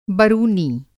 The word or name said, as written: बरुनी